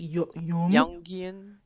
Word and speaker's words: Jungian: People, a lot of people use it, um, like Union. I can never say his name. Use it Jungian. – Jungian.